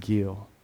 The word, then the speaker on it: gil: Rejoice, the Hebrew word is called gil, or pronounced gil.